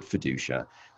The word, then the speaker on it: Feduccia: R-O-B-E-R-T. And then my last name is spelled F-E-D-U-C-C-I-A. So Robert Fiducia.